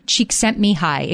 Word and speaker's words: Csikszentmihalyi: His name is Mikhail Csikszentmihalyi.